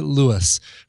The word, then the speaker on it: Louis: On the 29th of August is the birthday of my fiancé, Louis. For the first 3 months of our relationship, I did not realize he had a typical French name where the S is silent.